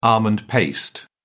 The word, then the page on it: almond paste: British English